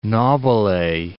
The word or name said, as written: Nobile